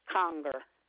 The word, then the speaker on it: Conger: Yes, it's Bonnie Conger. – Could you spell that last name for me, please? – It's C-O-N-G-E-R.